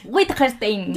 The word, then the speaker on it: Wittgenstein: Wittgenstein.